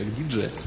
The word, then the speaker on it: Digia: Правильно произносится как Digia.